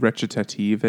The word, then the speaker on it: recitative: It's like, you know what recitative is in opera?